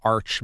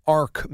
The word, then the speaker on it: Arch: He called Arch Manning Ark Manning.